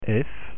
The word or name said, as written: f